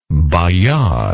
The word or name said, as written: Bayar